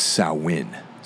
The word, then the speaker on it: Samhain: Well, for those of you who are in the know, it's Samhain. So I just didn't want to pronounce it that way, since most people probably say Samhain.